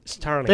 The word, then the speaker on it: Starlink: Lo pronuncié mal para Pesci igual. – Starlink. Pero no te voy a juzgar. Starlink.